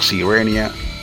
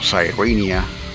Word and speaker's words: Sirenia: Se llama Sirenia o Sirenia, como se pronuncia correctamente.